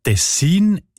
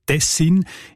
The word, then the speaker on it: Tessin: Die Variante mit der Betonung auf der ersten Silbe und mit kurzem I, also Tessin, ist seltener.